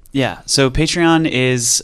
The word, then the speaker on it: Patreon: Patreon? Some folks, if you're from, you know, the UK or Canada, a lot of folks say Patreon.